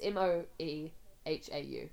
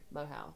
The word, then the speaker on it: Moehau: All right, so I'll tell you about the Moehau Man specifically. Most of the stories about him are from around the Coromandel. The Moehau, it's M O E H A U, Moehau.